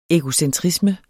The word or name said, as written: egocentrisme